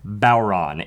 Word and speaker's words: Bodhrán: No, if I'm not mistaken, this is a Bodhrán, spelt like B-O-D-R-A with apostrophe H-N, but pronounced Bodhrán. It's Irish, so don't ask.